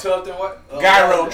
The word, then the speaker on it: gyro: Man, my bro turned me on to the Euro truck downtown. – The who? – Tell him. – Break it down. – Gyro truck. – Oh, okay. I'm a nigga. You gotta pronounce the G.